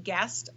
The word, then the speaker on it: Guest: and so that's at Nanci Guest RD,